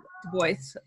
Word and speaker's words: Du Bois: And it's the Anglo pronunciation of Du Bois.